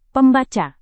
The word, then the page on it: pembaca: Indonesian